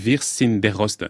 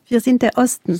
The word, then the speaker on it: Wir sind der Osten: L'année dernière, elle a lancé l'initiative Wir sind der Osten. Pardon, si je prononce pas correctement, vous le faites mieux? – Wir sind der Osten. – C'est beaucoup mieux.